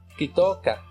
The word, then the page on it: kitooka: Náhuatl